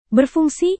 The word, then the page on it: berfungsi: Indonesian